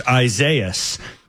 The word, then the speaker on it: Isaias: I know you all know it's pronounced Isaias.